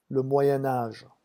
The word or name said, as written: le moyen age